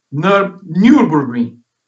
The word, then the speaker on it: Nürburgring: Imola, Nürburgring,